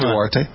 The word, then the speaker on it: Duterte: President Duterte, or how do you pronounce the name? Duterte? Called Obama. – Duterte.